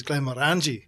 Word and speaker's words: Glenmorangie: and he heard me ordering it and he corrected me on the pronunciation. – Oh my gosh. – It's Glenmorangie.